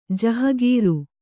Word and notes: ಜಹಗೀರಿ: jahagīri

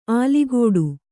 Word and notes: ಅಲುಗಾಡು: alugāḍu

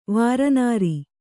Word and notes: ವಾರನಾರಿ: Kannada

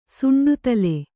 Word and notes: ಸುಂಡುತಲೆ: suṇḍu tale

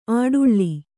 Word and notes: ಆಡುಳ್ಳಿ: Kannada

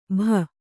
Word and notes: ಭ: bha

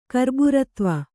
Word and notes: ಕರ್ಬುರತ್ವ: karburtva

